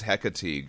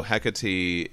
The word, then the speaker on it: Hecate: Well, so Hecate is the original pronunciation from Greek. When Shakespeare did Macbeth, he did Hecate. But the only reason he did that, what I've learned, is that he needed it to rhyme with the rest of his play.